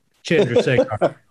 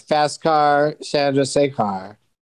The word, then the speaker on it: Chandrasekhar: What about like Fast Car, Chandrasekhar? – Okay, so that one, there's a guy from Venezuela who would teach other people how to pronounce the name because he would say John drives a car, but you say it in that Venezuelan accent, and he say John drives a car.